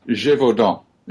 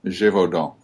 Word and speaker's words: Gévaudan: Gévaudan. Gévaudan. You wouldn't actually pronounce the N.